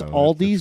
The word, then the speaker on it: Aldi's: It's Aldi's, it's really but you were saying.